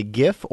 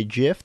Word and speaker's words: GIF: It's called a GIF or a GIF. There's two pronunciations.